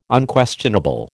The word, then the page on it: Unquestionable: American English